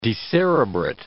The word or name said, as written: decerebrate